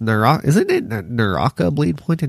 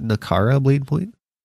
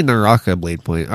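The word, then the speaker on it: Naraka Bladepoint: It's Naraka Blade Point and not Nakara Blade Point? Hold on. It's definitely Naraka Blade Point.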